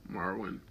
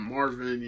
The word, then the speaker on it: Marwin: Yes, that's right, Marwin. I had to make sure I said his name right. Because sometimes it's like Marwin or Marvin, you know.